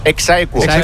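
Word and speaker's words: ex aequo: ¿Cómo se dice? – Exaequo.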